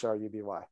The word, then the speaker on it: Hruby: And that's a silent H, so it's Hruby.